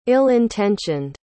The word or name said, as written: ill-intentioned